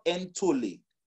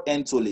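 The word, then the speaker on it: Entole (ἐντολή): It's the word entole. In 1 John 3, verse 22, it's the word entole. It's spelled E-N-T-O. L-E, Entole, yeah?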